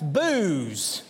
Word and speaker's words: Boaz: Now, I've learned in the past 2 weeks that while I would pronounce that word Boaz, here in Western Kentucky, there's some that would call it Bose. Or if you live in that town, perhaps it's Booze, is what I've heard.